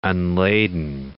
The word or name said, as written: unladen